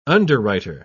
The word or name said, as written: underwriter